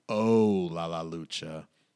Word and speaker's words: Ooh La La Lucha: It's spelled O-O-H, so it's more like Oh La La Lucha on Facebook.